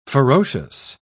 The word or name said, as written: ferocious